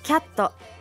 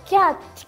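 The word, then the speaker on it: cat: 続いてキャット。皆さんはどのように発音しますか？ – キャット。 – キャット。 – もっと T はもっと。そうです。キャット。はい。 – キャット。 – そう。キャット。キャットじゃなくてキャット。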